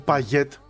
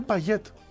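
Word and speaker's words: Payet: Ο Παγιέτ είναι Παγιέτ. Προφέρεται το τάφι. – Ναι, και εγώ έτσι το άκουγα και εδώ και λέγανε Παγιέ. Δεν είναι Παγιέ, είναι Παγιέτ. Μην με ρωτάτε γιατί.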